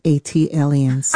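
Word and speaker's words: ATLiens: That is pronounced A-T-Aliens. – Ah, thank you. – Like Atlanta Alien, A-T-Alien.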